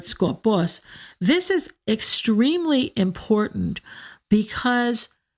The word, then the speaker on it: skopos: This is one that Chrysostom often mentions by name, and that is skopos, S-K-O-P-O-S, skopos. Put the accent on the second syllable, skopos.